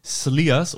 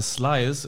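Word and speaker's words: Slyrs: Und es gibt nur einen Ursprung deutschen Whiskys, der ist natürlich am Schliersee und dieser Whisky heißt Sliers oder Slyers im Englischen ausgesprochen.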